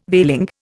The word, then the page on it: veiling: Indian